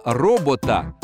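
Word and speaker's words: robota: Слово «робота» с ударением на первый слог по-чешски означает «принудительный труд».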